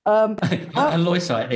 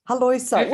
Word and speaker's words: halloysite: Halloysite, there we go. – Halloysite.